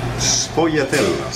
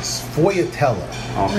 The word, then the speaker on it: sfogliatella: here, and then we're going to have a Sfogliatella. – Sfogliatella. – Okay. Yeah. So a lot of people don't know about sfogliatella.